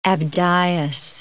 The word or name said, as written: Abdias